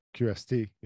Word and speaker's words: QST: Is it the QAS? – QST. Yeah.